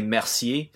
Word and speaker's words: Mercier: Well, it's Mercier. French. It's a French Canadian name, so I say Mercier. I have no idea how to say it in English.